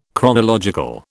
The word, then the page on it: chronological: British English